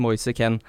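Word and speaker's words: Moise Kean: kom jo med en sånn oppfordring nå til liksom, sine kollegaer i Premier League at sånn, det uttales Moise Ken.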